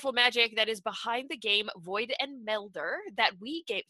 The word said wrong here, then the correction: Meddler: Sorry, Meddler. I'm going to say that wrong. I don't know why I want to say Melder, but it's Void and Meddler.